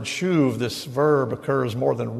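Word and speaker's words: shuv (שוב): The topic of repentance is covered in the Old Testament almost exclusively in one verb, and the verb is pronounced shuv.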